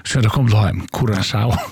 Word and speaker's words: Curaçao: Ja, och vi kommer att säga att landanavnet Curaçao uttalas Cura, K-U-R-A-S-A-U av vårskan Curaçao.